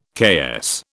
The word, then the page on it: KS: British English